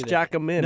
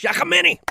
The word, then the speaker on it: Giacomini: Louis Giacomini. – No, that's not how you say his name. Trent gets excited. – I always say Louis Giacomini. But it's with a J sounding, and I've known Louis from the construction industry, I'd say 15, 20 years at least, yeah.